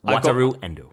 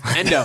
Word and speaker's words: Wataru Endo: Wataru Endo. – Thank you. – See, I butchered it for you. – Endo.